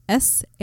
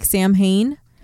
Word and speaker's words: Samhain: Samhain. So it looks like Samhain and it's not. It's pronounced Samhain.